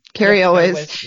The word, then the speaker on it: Cary Elwes: Is that how you say his name? Cary Elwes. Yes.